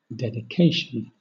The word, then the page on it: dedication: British English